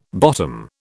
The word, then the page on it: bottom: British English